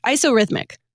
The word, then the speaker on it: isorhythmic: But for this hymn, they actually prefer the isorhythmic or isometric. I always say this wrong. They prefer Bach's setting of it, isorhythmic.